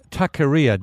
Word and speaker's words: Taqueria: Now, speaking of artisanal, you've produced a terrific book published by Hardy Grant called Taqueria. Did I pronounce that correctly? – You did very well, actually. Yeah, that's excellent.